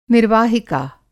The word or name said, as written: निर्वाहिका